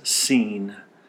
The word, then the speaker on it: Sin: Now they have come to the wilderness of Sin, actually pronounced as Sin in the Hebrew. So don't confuse it with our English word sin as it is written here.